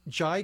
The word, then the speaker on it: Jai: To that extent, a young Nazi officer, played by Jai Courtney, am I pronouncing that right? – Yes. – I would say Jai or Jay, yeah.